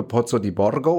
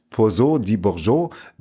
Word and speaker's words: Pozzo di Borgo: Der schreibt sich eigentlich wie ein italienischer Name Philippe Pozzo di Borgo oder so, aber er, aber er spricht sich Philippe Pozzo di Bourgeot, weil das nämlich ein französischer Unternehmer war,